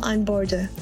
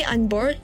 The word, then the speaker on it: unBORDE: Not long after Imyeon's first EP was released with this song, she switched from Lastra Music Entertainment to Warner Music Japan's sub-label Unborder. I say Unbored, but it's pronounced Unborde, whose roster includes Capsule, Kari Pyamu Pyamu, Tofu Beats, Wanima, and Indigo La'en, to name a few.